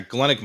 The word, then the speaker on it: Galenic: Is it Galenic medicine? Yeah, Galenic medicine.